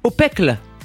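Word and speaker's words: Aupècle: Alors je ne sais pas si je vais bien prononcer son nom de famille, mais en tout cas c'est Victor Opecle. – Opecle. – Opecle. Ça s'écrit A U. P-E-C-L-E.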